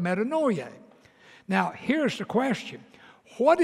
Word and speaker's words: metanoia: Like if you were going to write that just kind of like we would pronounce it, it'd be M E T A, meta, then noia, N O I, and then dash A, metanoia.